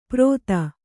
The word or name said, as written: ಪ್ರೋಥ